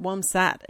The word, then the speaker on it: WomSAT: Uh, well, it just rhymes with wombat, womsat.